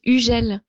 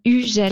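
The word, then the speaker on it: Hugel: Yeah, the pronunciation is Ugel. You don't say the H and the G is like J, so Ugel.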